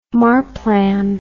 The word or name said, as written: Marplan